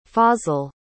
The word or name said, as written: fazal